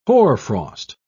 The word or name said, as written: hoarfrost